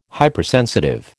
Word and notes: Hypersensitive: American English

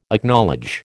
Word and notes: Acknowledge: American English

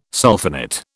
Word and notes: sulphonate: British English

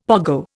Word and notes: pogo: Indian

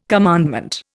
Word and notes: commandment: Indian

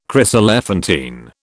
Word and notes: chryselephantine: British English

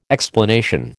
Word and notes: explanation: American English

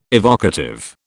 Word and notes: evocative: British English

